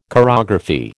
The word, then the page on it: chorography: American English